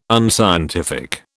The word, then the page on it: unscientific: British English